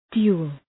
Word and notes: dual: ‘du:əl

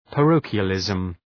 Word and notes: parochialism: {pə’rəʋkıəlızm}

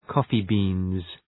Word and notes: coffee beans: ‘kɔ:fı,bi:nz

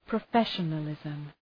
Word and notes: professionalism: prə'feʃənəlızm

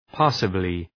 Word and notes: passably: ‘pæsəblı

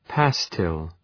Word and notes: pastille: ‘pæstıl

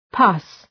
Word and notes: pus: pʌs

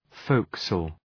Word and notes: forecastle: {‘fəʋksəl}

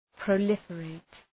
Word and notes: proliferate: prə’lıfə,reıt

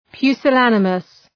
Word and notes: pusillanimous: ,pju:sə’lænəməs

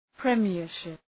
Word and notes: premiership: prı’mıərʃıp

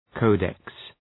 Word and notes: codex: ‘kəʋdeks

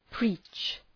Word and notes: preach: pri:tʃ

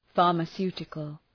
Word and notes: pharmaceutical: ,fɑ:rmə’su:tıkəl

